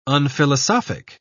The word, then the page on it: unphilosophic: British English